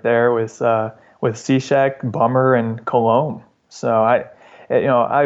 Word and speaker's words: Cishek: I know I'm going to pronounce his last name wrong, but Cishek. – Yes, Cishek? You got Cishek? It. Steve Cishek. – Cishek. There you go. Like a Cishek. – Cishek. Okay. Yeah. Shek. Okay. Like Shek West.